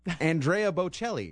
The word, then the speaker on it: Andrea Bocelli: Andrea Bocelli.